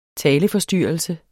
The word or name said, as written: taleforstyrrelse